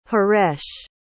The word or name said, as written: haresh